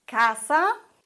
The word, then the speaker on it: casa: Wörter, Beispiele dafür sind casa, casa, vorletzte Silbe. Das Wort endet auf Vokal, auf A, und die vorletzte Silbe wird betont. Das ist in dem Fall die erste, casa, und kein Akzent.